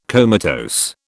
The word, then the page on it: comatose: British English